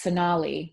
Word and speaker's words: Sonalee: I like always in my head imagine that your name is pronounced Sonalee, which is right, right? – Oh my God, that is the right way.